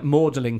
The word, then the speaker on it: Magdalen: it's like Magdalene College, but you read it and it says Magdalene College. It's like, oh no, but here we pronounce it Magdalen.